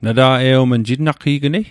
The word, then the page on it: jitnaqe'gnei: Mi'gmaq